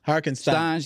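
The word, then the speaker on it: Hartenstein: How do you say it? – Stein. – Harkenstein. – Stein. Okay.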